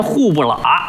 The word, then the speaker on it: 虎伯劳: 哎，老北京本地发音户不喇。哎，这写出来呀叫伯劳啊，老虎的虎，虎伯劳，念出来户不喇。